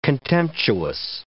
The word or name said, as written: contemptuous